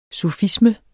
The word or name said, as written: sufisme